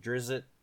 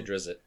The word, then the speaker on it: Drizzt: I mean, back in the day, my favorite was Ranger Rogue, and that's because I was a fan of Drizzt. How the fuck you want to pronounce that fucker's name? I say Drizzt. – That's how I've always said it, Drizzt.